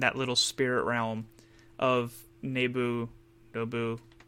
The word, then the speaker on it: Nabu: It's pronounced it's spelled N-A-B-U. Oh, okay. So I want to say Nabu. – Okay. Maybe Nabu. – Nabu, that's what it was.